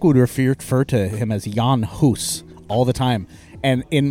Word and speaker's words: Jan Hus: But it's pronounced Jan Hus.